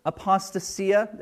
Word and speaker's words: apostasia: Well, the Greek word that Paul uses for falling away is apostasia. There are other ways to pronounce it. That's how I'll pronounce it.